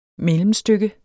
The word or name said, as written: mellemstykke